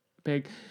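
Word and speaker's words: Beghe: No, because this has got Jason, and I never pronounce his name right, but it's Beg or Big. Beg.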